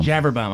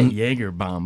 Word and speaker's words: Javerbaum: Javerbaum. I was going to say Jagerbaum, but Javerbaum.